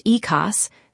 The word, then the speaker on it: IQOS: IQOS, pronounced EKOS,